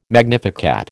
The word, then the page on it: magnificat: American English